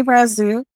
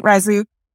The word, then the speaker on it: Razzouk: We have Jay Razouk. Am I saying that right, Razouk? – You nailed it.